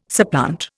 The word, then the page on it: supplant: Indian